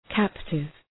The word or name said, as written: captive